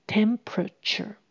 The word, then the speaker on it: temperature: 是啊，气温已经下降到八度 C 了。Temperature 就是指温度。第二个 e 通常不会念，也就是 p r a 合起来念一个音节，pr pr t u r e 念成 cher。Temperature temperature